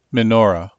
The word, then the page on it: menorah: American English